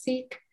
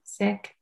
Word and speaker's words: Sikh: I'm really glad you asked that, because that is a question that most people ask, because I think predominantly when you hear the word Sikh, you hear it pronounced as Sikh. The correct way to pronounce it is Sikh.